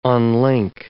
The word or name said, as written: unlink